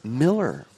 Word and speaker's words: Moeller: And, we've got number 26, A Flock of Parrotheads by Gary James Moeller. – I knew you were going to catch that one. It's Miller. He pronounces it Miller, M-O-E-L-L-E-R.